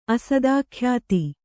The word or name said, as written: ಅಸದಾಖ್ಯಾತಿ